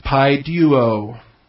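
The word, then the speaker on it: pi-duo (παιδεύω): A-I-D are pretty readable. E is pretty readable. U is all right. That omega at the end is what looks like a W. It's just the long O, omega. So it's pi-duo.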